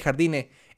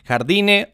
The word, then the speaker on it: Jardine: Es el brasileño André Jardine o Jardine, me parece más bien por eh, su acento, por la pronunciación correcta en portugués.